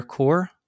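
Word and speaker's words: Chur: Starting off in with Big Air Core. Is that how you say it? Core? – Yeah, it's like C-H-U-R. I don't know. Yeah. I've heard a lot of different ways. Like core or...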